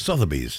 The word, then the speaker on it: Sotheby's: Sotheby's? – Sotheby's. – Sotheby's.